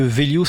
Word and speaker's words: Vhelio: On va préciser que Vélio, ça s'écrit V-H-E-L-I-O.